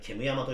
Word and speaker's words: 煙山: 煙煙山煙山っていう名字珍しいですよ煙の山と書いて煙山と読むらしいですよ。